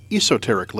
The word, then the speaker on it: Esoteric: The British Esoteric, or as they pronounce it, Esoteric label, reissued this classic back in 2011.